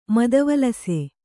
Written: ಮದವಲಸೆ

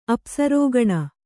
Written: ಅಪ್ಸರೋಗಣ

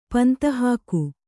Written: ಪಂತಹಾಕು

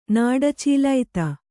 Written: ನಾಡಚೀಲೈತ

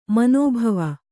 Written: ಮನೋಭಾವ

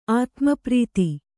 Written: ಆತ್ಮಪ್ರೀತಿ

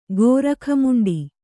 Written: ಗೋರಖಮುಂಡಿ

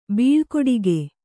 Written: ಬೀಳ್ಕೊಡಿಗೆ

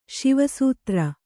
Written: ಶಿವಾಸ್ತ್ರ

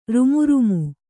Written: ರುಮುರುಮು

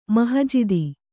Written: ಮಹಜಿದಿ

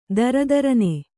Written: ಥರಥರನೆ

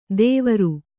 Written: ದೇವರು

